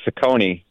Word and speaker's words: Ciccone: Well, first of all, and you pronounced it correctly, by the way, Ciccone. But I gave him some grief, and I have the same issue in my family. Like, we're pronouncing our names wrong. Our ancestors would be furious. You know, I should be Castrovince and he should be Ciccone.